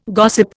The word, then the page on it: gossip: Indian